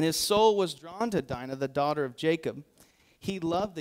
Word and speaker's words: Dinah: It says, "Now Dinah," I'm going to mispronounce that. It's Dinah, but I've been thinking Dina. Every time I read this, I'm thinking Dina.